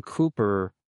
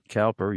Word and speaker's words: Cowper: But anyway, we have Carl in Denton, Texas, who says, you accidentally brought up William Cowper earlier, and by the way, that's how it's pronounced, not Cowper.